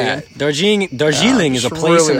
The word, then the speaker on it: Darjeeling: It's Darjeeling. Darjeeling. – D-A-R-J-E-E-L-I-N-G.